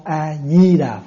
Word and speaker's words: A Di Đà: Nam Mô A Mi Đà Phật. Rồi cái người Việt mình đọc lại là Nam Mô A Di Đà Phật. Mà trong khi đó người Hoa đọc là Mi chứ không phải Di.